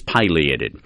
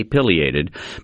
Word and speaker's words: pileated: I will say that the correct pronunciation is pileated.